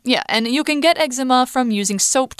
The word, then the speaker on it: eczema: Quick note: uh, in the UK, the this particular skin condition is more usually pronounced eczema.